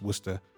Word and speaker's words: Worcester: So Worcestershire is Worcester.